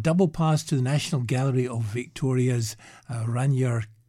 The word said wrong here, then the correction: Ragnar: Kjartansson. – Pronunciation's right next to the word. – Oh, Ragnar Kjartansson.